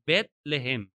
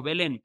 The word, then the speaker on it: Bethlehem: En realidad la pronunciación es Bethlehem. Esa sería la forma correcta. Nosotros en español le decimos Belén, pero es lo mismo.